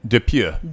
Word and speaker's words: Dupieux: Quentin Dupieux? Is that how you pronounce it? – Dupieux.